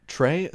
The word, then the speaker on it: Trey: Trey? C-H-R-E-Y? No, it's just... That's how it's pronounced. – But oh, like a tray of food. No, it's with an E.